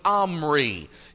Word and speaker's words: Omri: His father's name was Omri. It's spelled O-M-R-Y. It's pronounced Omri.